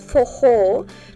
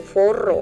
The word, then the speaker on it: forró: Bueno, precisamente te quería preguntar sobre eso, porque tú dices fojó, pero se escribe F-O-R-O, que digamos en español lo leeríamos como forro o forró, ¿cierto? Sí. Pero se pronuncia sí. Fojó. – Sí, en portugués lo que es la doble R se pronuncia como H en inglés o J en español, fojó.